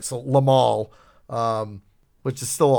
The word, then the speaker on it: Limahl: I, am pretty sure his name is pronounced Limahl, not Lionel.